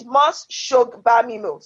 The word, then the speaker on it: Mos-Shogbamimu: Mos-Shogbamimu? I mean, just read it phonetically. Shogbamimu, not shag, because some people just roll it. – No, move on. – Mos-Shogbamimu.